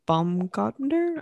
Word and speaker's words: Baumgartner: Like, I'll never forget, like, watching live, like, the Felix Baumgartner. I'm not pronouncing that last name. – I believe you're right.